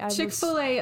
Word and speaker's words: Chick-fil-A: Chick-fil-A, a play on the American English pronunciation of filet.